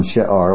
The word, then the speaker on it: She'ar: This is the term She'ar, like we saw in Isaiah 10, the word for remnant.